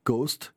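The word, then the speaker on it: KOST: Le reste du temps, Coast, K-O-S-T, K-O-S-T pour le call letters, mais prononcé phonétiquement Coast comme la côte, sous-entendu West.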